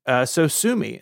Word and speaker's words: Sosumi: SoSumi. It's spelled SOSUMI. So it doesn't look like SoSumi, but it sounds like SoSumi.